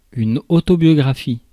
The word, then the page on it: autobiographie: [y.n‿ɔ.tɔ.bjɔ.ɡʁa.fi]; /o.to.bjɔ.ɡʁa.fi/